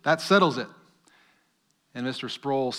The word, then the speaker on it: Sproul: Sproul, I pronounced it wrong in the first service. I said Sproul, and I was severely reprimanded. It's Sproul.